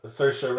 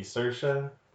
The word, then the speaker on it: Saoirse: Even the famous uh, Saoirse Ronan. Um, like she's always correcting people how to pronounce her name, but she pronounces it incorrectly. she's like it rhymes with inertia, so it'd be Saoirse, but no, no you don't know how to pronounce your own name, Dan.